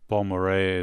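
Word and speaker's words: Balmorhea: Bon Marais is the name of the band.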